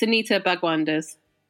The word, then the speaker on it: Bhagwandas: How do you say it? – I mean, yeah, that is basically it. It's Anita Bhagwandas.